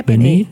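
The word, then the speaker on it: BENEE: Benny heißt die. Ja, ist das richtig ausgesprochen? – Ich denke, ja, Benny. Benny, ja.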